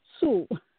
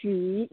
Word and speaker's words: Hsu: So Borton, she, last name is H-S-U. – Okay. – I know a lot of Americans, pronounce Sue. We pronounce she.